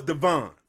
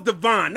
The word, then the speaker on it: Devon: D-E-V-I-N. O is Devon. O is Devon, not Devin.